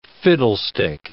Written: fiddlestick

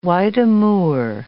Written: white amur

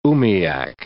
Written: umiak

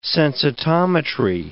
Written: sensitometry

